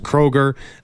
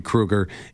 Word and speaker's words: Kroeger: It's Kroeger. It's commonly referred to as Kroeger, but it's actually Kroeger.